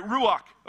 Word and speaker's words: ruach: All right, ruach, okay, there it is.